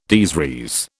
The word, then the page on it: des res: British English